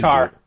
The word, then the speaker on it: tire: And they would say, like, they say tire in a really different way. How do you say you can tar. – Tar, yeah. Like, T-A-R is how it's pronounced.